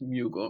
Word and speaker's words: Mugele: Last name's Mugele. It rhymes with bugle, which is a question a lot of people ask me.